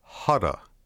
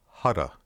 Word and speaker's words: hutta: Now be aware that when we say the syllable hutta, that's what I call a soft T or a quick T, as opposed to a hard T, which would be hutta.